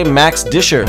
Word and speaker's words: Max Discher: My guest today, Max Discher. Did I say that right? – You got Discher? It right.